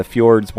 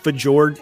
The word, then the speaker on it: fjord: I always found it odd that the fjords weren't more of a highlight. Nah, give us oil rigs instead, huh? Only things... – By the way, I believe it's pronounced fjord. – Fjord.